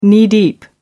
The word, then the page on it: knee-deep: American English